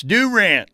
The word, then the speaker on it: Durant: It's Durant, of course, but it's Durant in Oklahoma speak.